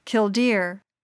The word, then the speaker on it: killdeer: Their name derives from their call, except they accent the second syllable, killdeer.